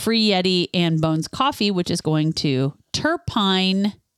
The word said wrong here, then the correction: Turpin: Turpin? – Turpin. Is that how you say it? – Yeah, well, that's how Turpin, we always said it in Kansas.